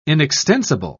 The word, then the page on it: inextensible: British English